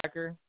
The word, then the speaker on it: Hacker: Josiah Hacker.